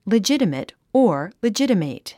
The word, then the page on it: legitimate: lidʒítəmət; リジタメト